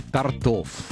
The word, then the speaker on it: тортов: Торты или торты? – Торты. – Тортов или тортов? Как мы все-таки... – Тортов. – То есть торты в первом случае, а во втором случае тортов.